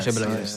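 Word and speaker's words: Hajeb El Ayoun: À Ajeb El Ayoun. Voilà. – Ajeb El Ayoun. Voilà. La prononciation n'est pas la même.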